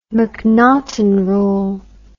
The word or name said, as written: mcnaghten rule